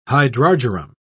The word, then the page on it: hydrargyrum: British English